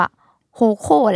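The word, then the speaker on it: HOCO: มันน่าจะอ่านว่าโฮโคแหละมันเป็น H O C O ดอทเราขออ่านว่าโฮโค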